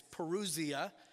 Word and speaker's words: parousia: It's parousia. P-A-R-O-U-S-I-A, parousia, or you can just phonetically spell it however it sounds.